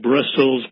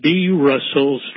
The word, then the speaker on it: B. Russell's: Russell's, you could call it Brussels, but it's B. Russell's Tribunal, and it's spelled capital B, capital R, USS, ELS, the B.